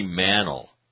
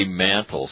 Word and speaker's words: Mantle: Yeah, Mickey Mantle. Not Mickey Mantle. Well, it looks like Mantle. There's an E after the A, so doesn't that make the E a long E? No. They say Mantle, so it's Mantle.